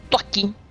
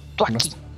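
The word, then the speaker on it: Thwackey: Grookey evoluciona en Tuaki Ahí discúlpenme mi pronunciación de los nombres porque al igual de que los diseños ya volvieron un poco extraños.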